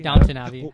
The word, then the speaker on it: Downton Abbey: Downtown Abbey? Downton Abbey? – Downton Abbey.